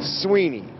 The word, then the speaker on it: Swinney: And I know it's spelled Swinney, but it's Sweeney. Don't ask me why. I have no idea. But that's how you say it.